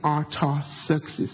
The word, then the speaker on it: Artaxerxes: And even Nehemiah was in King Artaxerxes, that's how you pronounce the name.